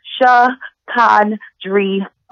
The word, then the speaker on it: Chicondria: It's pronounced Chicondria. No silent letters, no accents, preferably pronounced with the drawl of a southern accent.